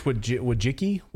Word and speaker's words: Wojcicki: Now, under how do we say her name again? You say Wojcicki? – I think it's Wojcicki.